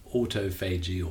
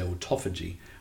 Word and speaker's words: autophagy: And the repair process is called autophagy, or the Americans say autophagy, autophagy, self-eating.